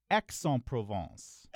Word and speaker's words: Aix-en-Provence: I think it's Aix-en-Provence. – Excellent. – Oh, excellent. – That's why I said excellent. Yes. – And it's tricky because you kind of want to overcorrect, right, and not pronounce the X.